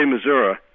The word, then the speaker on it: Missouri: I say Missouri, which is to say I pronounce it correctly.